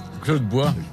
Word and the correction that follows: Dubois: Non, ça c'est vous. Claude Dubois. – Faut tout dire. Faut dire toutes les syllabes.